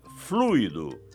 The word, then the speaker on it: fluido: Repare, o isqueiro está sem fluido, sem acento, e a pronúncia é fluido, com a tônica no U.